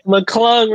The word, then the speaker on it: McClung: Let's get to everybody's guy of the weekend, you know, Mac McClunkin. That's how you say his name? – Mac McClung, return of the Mac.